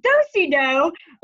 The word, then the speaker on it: do-si-do: And so they looked at the card and I pronounced do-si-do incorrectly. I pronounced it the C-O. – No. – Yes. And they were like, oh, they go, that is not the city of winter. That is do-si-do.